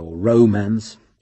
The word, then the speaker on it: romance: romance, always with the accent on the O, Gordon was ready with expert advice.